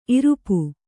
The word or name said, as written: ಇರುಪು